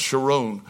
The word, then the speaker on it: Sharon: This is called the Rose of Sharon. Sharon, like Ariel Sharon, the prime minister that was once in office. We call it the Rose of Sharon, but it's actually pronounced Sharon.